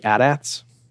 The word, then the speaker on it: AT-AT: But I think the biggest sound for me that's new is the AT-ATs, which I pronounce it AT-AT. And so I was going to ask you if you pronounce it AT-AT or AT-AT. – Okay, see, there you go. And I wonder, this is the thing I've heard with too, that this might be a generational thing, because people my age grew up with the commercial where the kids would say, oh, this is an AT-AT or whatever. So, because it's never pronounced in the films, but it's it's an AT-AT and yet it's an AT-ST.